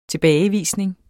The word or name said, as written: TILBAGEVISNING